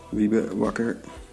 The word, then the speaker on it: Wiebe Wakker: Wiebe Wakker.